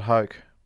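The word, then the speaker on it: Hoch: Scott Hoke was in a playoff with Nick Faldo. He had a 1.5-foot putt to win the Masters. And when your name rhymes with choke...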